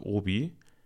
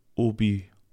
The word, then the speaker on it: Obi: Jeder kennt den Baumarkt Obi. Der Name kommt ursprünglich aus Frankreich und geht auf das französisch ausgesprochene Hobby zurück. Also Obi, Hobby.